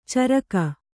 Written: ಚುರುಕ

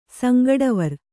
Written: ಸಂಗಡವರ್

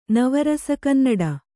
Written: ನವರಸಕನ್ನಡ

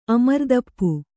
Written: ಅಮರ್ದಪ್ಪು

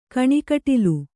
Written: ಕಣ್ಕುಟಿಲ

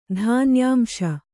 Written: ಧಾನ್ಯಾಂಶ

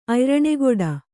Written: ಅಯ್ರಣೆಗೊಡ

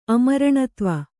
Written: ಅಮರಣತ್ವ

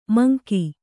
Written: ಮಂಕ

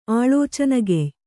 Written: ಆಳೋಚನಗೆಯ್

